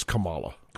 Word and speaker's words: Kamala: And I don't know who the person they would anoint because they don't want Kamala, Gavin Newsom get on me for not pronouncing that correctly. But I mean, look, man, I'm from the South. We've had this conversation. It's Kamala.